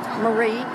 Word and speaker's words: Marie: D-A-V-E, David Sandifer, S-A-N-D-E-F-U-R. – Okay, can I get your name, please? – Marie Pruitt. – Can you spell it? – M-A-R-I-E-P-R-U-I-T-T.